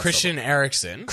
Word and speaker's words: Christian Eriksen: I cannot pronounce Christian Eriksen. – Christian Eriksen.